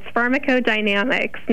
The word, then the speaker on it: pharmacodynamics: Am I close on that? Pharmacodynamics? How do I say that? – It is. You were right on the money. It's pharmacodynamics. Nicely done.